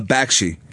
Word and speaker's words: Bakshi: How do you say his last name? Basqui? – Bakshi.